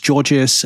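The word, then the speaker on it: Gorgias: I kept on calling Gorgeous, just because of the way it's spelled, G-O-R-G-I-A-S, I think. So it's Gorgeous, you know, the CX platform, the customer service, you know, platform for Shopify, Magento, and BigCommerce.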